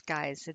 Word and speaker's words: Gies: The Geis, and I'm pronouncing this Geis, it is spelled G-I-E-S.